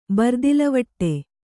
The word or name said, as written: ಬರ್ದಿಲವಟ್ಟೆ